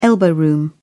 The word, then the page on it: elbow room: British English